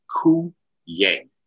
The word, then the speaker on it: Cuillier: Just ignore the letters. And It's Cuillier.